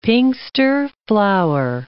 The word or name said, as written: pinxter flower